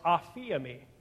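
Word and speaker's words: aphiemi (ἀφίημι): In the original Greek, it's pronounced aphiemi. Aphiemi.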